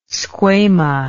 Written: squama

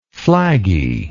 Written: flaggy

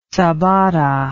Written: sabata

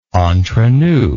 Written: entrenous

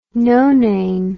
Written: nonane

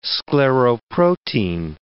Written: scleroprotein

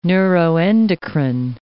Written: neuroendocrine

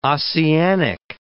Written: Ossianic

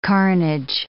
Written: carnage

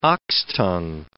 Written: oxtongue

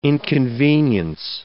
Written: inconvenience

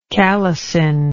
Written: calycine

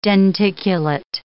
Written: denticulate

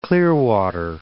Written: Clearwater

